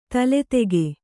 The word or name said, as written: ತಲೆದೂಕ